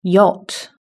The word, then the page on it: yacht: British English